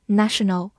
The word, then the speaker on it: national: 而在末尾加上 a l 这样一个形容词后缀，就变成今天我们学习的 national。字母 a 的发音变成了大口 a。